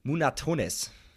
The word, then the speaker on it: Munatones: Ähm, naja, äh, erfunden hat das Ganze der US-Amerikaner Steven, er wird spanisch ausgesprochen, habe ich mir sagen lassen, Munatones. Christoph.